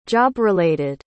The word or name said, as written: job-related